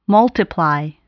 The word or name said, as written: multiply